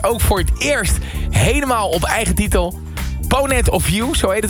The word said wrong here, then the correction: Point of View: Hoe moet ik het uitspreken eigenlijk? – Ja, doe maar Point of View. – Point of View is beter.